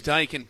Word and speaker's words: Daikin: It is Daikin Park, but yeah, it just doesn't have the same ring to it.